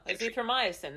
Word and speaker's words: azithromycin: Oh, azithromycin.